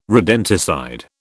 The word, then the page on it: rodenticide: British English